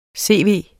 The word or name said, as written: CV